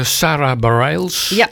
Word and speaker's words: Bareilles: En dat doet Sarah Bareilles, als ik het goed uitspreek. – Klopt.